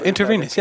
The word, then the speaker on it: intravenous: That's, yeah, intravenous, I pronounce it wrong.